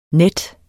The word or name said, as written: nette